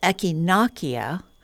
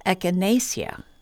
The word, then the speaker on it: echinacea: My English pronouncing dictionary of plant names says echinacea, while my American herb book says echinacea.